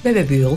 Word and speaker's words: Bebe Buell: Su madre, la ex modelo y cantante Bebe Buell, ¿la pronuncié bien? – Bebe Buell, sí.